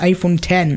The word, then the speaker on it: iPhone X: Alors ça s'écrit iPhone X, mais ça se prononce iPhone 10.